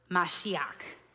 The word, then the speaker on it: Mashiach: The Hebrew word for the anointed one is Mashiach, or as we pronounce the word in English, Messiah.